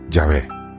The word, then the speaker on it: Yahweh: Estas traducciones y nuestro conocimiento del verbo hebreo ser, que es HAYAH, cuando se incluyen las vocales es el resultado del nombre Yahweh es lo más cerca que podemos llegar a la pronunciación del nombre de Dios que fue revelado a Moisés.